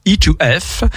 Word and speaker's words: E2F: Alors attention, il a bien noté, il faut le prononcer à l'anglaise, donc je vais le faire. e to f